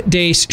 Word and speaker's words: Deace: You can like us on Facebook, follow us on Twitter, at Steve Dace Show, and the last name is spelled D-E-A-C-E.